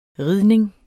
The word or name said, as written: ridning